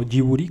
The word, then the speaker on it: Ghibli: Y fíjate que Estudio Ghibli, o Ghiburi, como sería la pronunciación correcta según yo, tampoco es como que sea perfecto.